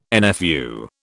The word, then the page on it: NFU: British English